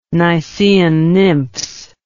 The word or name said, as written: nysaean nymphs